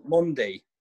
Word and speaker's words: Mendy: But before we wrap up on the game against West Ham entirely, can we just talk about Mendy? – Quickly? – Mondi. – Mondi. – It was Mondi.